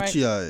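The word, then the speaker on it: Ochiai: And that took Ochai. Am I pronouncing that Ochi-ai. – Right? Ochi-ai. Sorry. Yeah, that's right.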